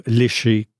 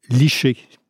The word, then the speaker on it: lécher: Nous savons tous ce que signifie le verbe « lécher », que l'on prononce très souvent chez nous « licher » dans le langage populaire.